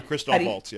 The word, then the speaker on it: Christoph Waltz: Is it Christoph Waltz? Is that how you say his? Oh, you mean the actor. – Right. – Yeah, Christoph Waltz, yeah.